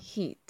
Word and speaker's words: heat: Hit. Si nos damos cuenta, esta como otras palabras que incluyen dos vocales, nuestra primera impresión sería pronunciar las dos vocales, como se hace en castellano.